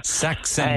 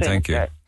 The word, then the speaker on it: Saxenda: So I'm being so well looked after with this Saxenda. – Saxenda and is the correct pronunciation. – Saxenda.